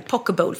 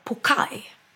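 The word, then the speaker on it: poké: Men då uttalar jag den helt fel, pokeball. För den uttalas pokai. – Va? – Ja. – Pokeball. – Hur konstigt. Stavas pokeball med W och uttalas pokai.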